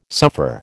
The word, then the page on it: suffer: American English